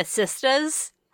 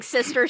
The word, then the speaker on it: sista: No, I just it's kind of funny because their big line from their big song is their Cheetah Girls, Cheetah Sistas. – So true. – Pronounced like sister, sister. But sista, sista.